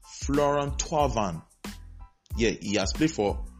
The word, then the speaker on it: Thauvin: It's pronounced Florent Thauvin, not Florent Thauvin. The way it is spelled, it's not pronounced the way it is spelled. It's not Thauvin.